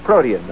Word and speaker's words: protean: Mr. Melton says, Oh, the word is pronounced protein. Is this uh, correct, Mr. Shepherd? And I said, Yes, it's protean. Uh, it's protean. Swell. Word is pronounced protean.